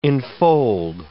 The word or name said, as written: enfold